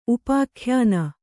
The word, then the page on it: ಉಪಾಖ್ಯಾನ: upākhyāna; kannada